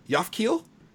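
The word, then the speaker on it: Japhkiel: The presenter is the mayor of Tokyo, when he tells the group that the robot is called a Yafkiel.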